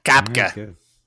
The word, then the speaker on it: Koepka: It's not Koepka. It's Koepka. Koepka. Here's a correction. It's Koepka, not Koepka. – It's weird. It's like Sapko and Kafka had a baby, and they named it Koepka.